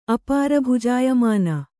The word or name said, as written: ಅಪಾರಭುಜಾಯಮಾನ